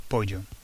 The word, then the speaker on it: pollo: Because chicken is pollo. It looks like polo, but it's pronounced pollo.